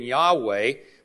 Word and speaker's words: Yahweh: We do not know how it was pronounced. And when the Jews would write the name of God in Hebrew, they would spell it YHWH. In Hebrew, there are no vowels, only consonants. And if you ever did pronounce it, most likely it would have been Yahweh, but they have anglicized it as Jehovah, referring to this Old Testament identification for God we find about 4,000 times in the Old Testament.